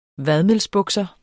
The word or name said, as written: vadmelsbukser